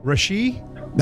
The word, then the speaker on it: Rashee: Rashee? – That is correct.